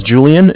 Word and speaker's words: Julian: My name is Julian. It's J-U-L-I-A-N. – Huh? – And I'm calling from Bank of Cardiff. It's spelled C-A-R.